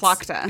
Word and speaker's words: Tlachtga: Her name is spelled T-L-A-C-H-T-G-A. – Oh, yeah. You asked me for the pronunciation of this one. – Yeah, yes. It's Clochta.